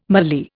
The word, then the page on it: mallee: Indian